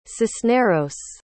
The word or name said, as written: cisneros